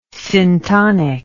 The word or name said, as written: syntonic